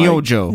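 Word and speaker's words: Nyūjō: Nyojo. – Nyojo. – Nyojo. N-Y-U-J-O.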